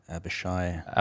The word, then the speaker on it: Avishai: I'm going to try to say his name. Avishai. It's A-V-I-S-H-A-I. – Avishai. – Avishai, is that right? That sounds better. – That's how I would say it.